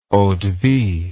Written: eau de vie

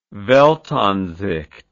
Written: weltansicht

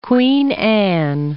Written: Queen Anne

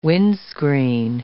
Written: windscreen

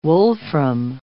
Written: wolfram

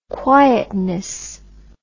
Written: quietness

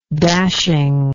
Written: dashingness

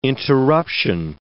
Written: interruption

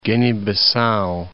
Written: Guinea-Bissau

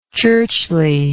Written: churchliness

